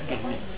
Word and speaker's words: Quesnay: Quesnay, se escribe con Q, ¿eh? Quesnay y con Y al final. Se pronuncia Quesnay, François Quesnay.